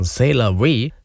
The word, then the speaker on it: C'est la vie: Also der neue Song von Joe Nichols heißt C'est la vie, also amerikanisch ausgesprochen C'est la vie, auch so geschrieben.